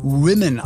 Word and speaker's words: Women: Und wenn ihr sagt, was ist denn Women, das ist das Wort Woman, so wie ihr es kennt, aber die Mehrzahl von Frauen auf Englisch spricht sich aus Women, also Women Up.